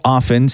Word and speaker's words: often: But I don't, this is my problem, is I don't often say things to just appease the people around me. – Well, you didn't pronounce the T in often.